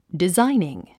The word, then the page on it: designing: dizáiniŋ; ディザイニン